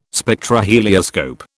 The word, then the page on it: Spectrohelioscope: British English